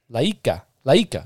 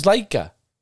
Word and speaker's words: Laika: Laika. It was Laika.